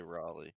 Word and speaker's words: Raleigh: Um, and then there was the oh, uh, Halo competitive um, Rayleigh event this weekend, if that's how you say it. Rayleigh, Rayleigh, Riley? – I don't know. I hear a lot of people say Raleigh. – Raleigh.